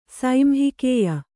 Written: ಸೈಂಹಿಕೇಯ

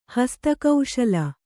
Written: ಹಸ್ತಕೌಶಲ

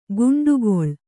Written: ಗಂಡುಗೊಳ್ಳು

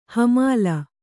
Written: ಹೆಮ್ಮಲೆ

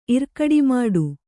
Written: ಇರ್ಕಡಿಮಾಡು

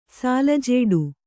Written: ಸಾಲ್‍ಝಾಡ

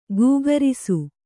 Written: ಗೂಗರಿಸು

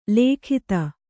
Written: ಲಗತು

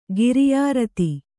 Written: ಗಿರಿಯಾರತಿ

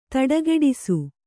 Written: ದಟ್ಟಿಗಟ್ಟಿಸು

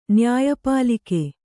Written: ನ್ಯಾಯಪಾಲಿಕೆ

